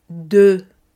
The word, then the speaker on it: deux: Con el número 2 es DE, DE, D, E, U, X. No pronunciamos la X en este caso, pero tenemos que hacer el sonido de E.